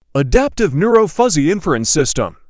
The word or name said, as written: adaptive neuro fuzzy inference system